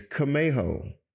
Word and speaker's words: Camejo: People look at my last name, Camejo, and they're like, first of all, it's kind of hard to pronounce when you first see it, right? So it's Camejo, C-A-M-E-J-O.